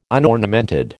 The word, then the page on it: unornamented: American English